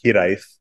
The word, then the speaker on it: Hiraeth: And this idea of Hiraeth, it's actually pronounced Hiraeth, kind of came across our plate really early on.